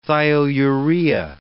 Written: thiourea

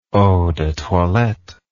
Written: eau de toilette

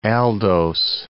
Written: aldose

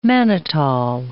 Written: mannitol

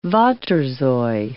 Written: waterzooi

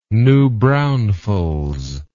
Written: new braunfels

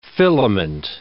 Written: filament

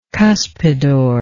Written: cuspidor

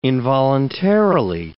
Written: involuntarily

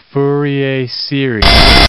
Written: Fourier series

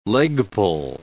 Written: leg-pull

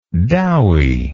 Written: dowy

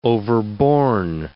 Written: overborn

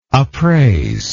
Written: appraisable

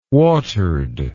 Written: watered

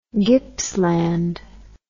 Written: gippsland

